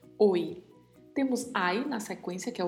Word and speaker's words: hoy: Também é escrito no início com a letra H, que não tem som, e ao final com a letra Y, que pronuncia-se também com som de I.